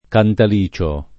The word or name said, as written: Cantalicio